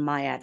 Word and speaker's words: Miar: You can find me also on LinkedIn at uh, Leanne Miar.